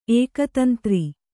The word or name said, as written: ಏಕತಂತ್ರಿ